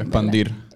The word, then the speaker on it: expandir: expander su música y llega. a... Expander, lo dije bien. – Expandir. Expandir. Puñales.